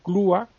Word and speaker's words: Clua: Se llama Jaime Ferrán y Clua, y griega Clua, con acento en la U.